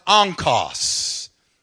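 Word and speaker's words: onkos (ὄγκος): The word weight that is used in this passage is a Greek word that is pronounced onkos. Onkos.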